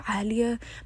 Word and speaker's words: Alia: And so Alia, my name is spelled like A-L-I-A, right? And people assume that it's like Alia, anything like that. And I'm like, no, guys, you're pronouncing my name wrong.